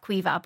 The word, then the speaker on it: Caoimhe: People like I call myself Caoimhe, but there are people who call themselves Caoimhe.